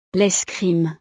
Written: l'escrime

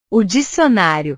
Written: o dicionario

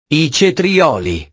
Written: i cetrioli

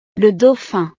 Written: le dauphin

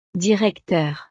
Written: directeur

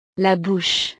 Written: la bouche

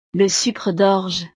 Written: le sucre dorge

